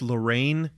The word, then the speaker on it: L'Rain: This is the artist Lorraine. It's spelled L'Rain.